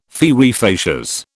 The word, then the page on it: fieri facias: British English